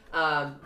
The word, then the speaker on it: Nakula: And so the brothers, the remaining brothers, which are now Yudhisthira the oldest, Bhima, Arjuna, and Nikula, right? Nikula? Nakula, not Nakula.